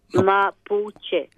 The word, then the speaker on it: Mapuche: Were the Maputo, can I pronounce it? – Mapuche. – Mapuche. Were the Mapuche...